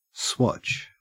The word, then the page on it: swatch: Australian English